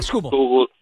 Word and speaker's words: Skubal: Skubal.